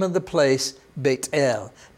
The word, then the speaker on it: Beit El: Now, I know your English Bible says Bethel. That's fine if you want to pronounce it that way. In Hebrew, remember, they don't have the TH sound like thee or those or these. They don't have that sound, but you pronounce the T as T instead. So Beit El is how you would say that.